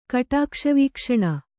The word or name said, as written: ಕಟಾಕ್ಷವೀಕ್ಷಣ